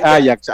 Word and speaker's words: Ajax: Sí, Ajax. Allá porque, porque es, es neerlandés. No Por eso, neerlandés. el, la, la, pronunciación correcta es eh, Ajax.